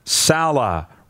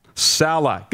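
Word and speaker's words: Saleh: From DG in Saskatoon, he says, hey, Roddy, number one, it's Robert Sala. Rhymes with Lady Gaga. Sala.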